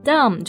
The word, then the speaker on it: dumb: Dumb 就是指的哑的。那这里要注意这个单词的末尾 B 是不发音的。Yeah. It's a silent B, yeah.